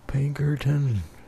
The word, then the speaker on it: Pinkerton: Pinkerton. – How do you spell it? – P-I-N-K-E-R-T-O-N.